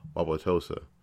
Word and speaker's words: Wauwatosa: Uh, Wabutosa, wow, wow, Wauwatosa, after a police officer wasn't charged with a shooting that led to the death of 17 year old Alvin Cole.